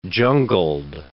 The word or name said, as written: jungled